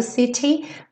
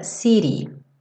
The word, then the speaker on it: city: Então, esse city poderia ser cantado city, mas tradicionalmente no inglês americano esse T vai ser reduzido para rarara, city.